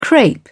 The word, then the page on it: crape: British English